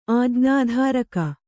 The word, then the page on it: ಆಜ್ಞಾಧಾರಕ: ājñādhāraka